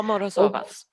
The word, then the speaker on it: Morozovas: General Kirigan vill hitta Morozova, Morozovas, kanske uttalas det, Morozovas gjort. – Ja, Morozovas. Och, Morozovas, eller hur? Tack.